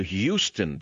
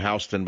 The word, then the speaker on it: Houston: Let's go to Houston, Texas. Some call it Houston, but it's Houston. It's still called Houston, even though in New York they call it Houston Street.